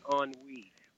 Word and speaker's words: ennui: I said, oh, my God, there's that word from 30 years ago, E-N-N-U-I. And if I... – Right. – I pronounce it on we. Am I wrong? – On we. Okay, on we.